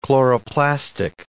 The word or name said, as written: chloroplastic